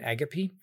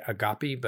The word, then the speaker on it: agape: In part, it's a response to Anders Nygren about Eros and Agape. That's how Lewis pronounced it, by the way. We usually here in this country say agape, but agape is how he pronounced it.